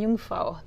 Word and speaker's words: Jungfrau: Eiger, Mönch, and Jungfrau.